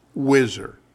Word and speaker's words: WHSR: Situation Room has given way to WHSR, pronounced Whizzer, for White House Situation Room.